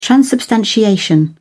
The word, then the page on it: transubstantiation: British English